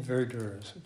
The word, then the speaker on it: verdure: Meadows, a simple, beautiful word, although verdure rhymes with nurture.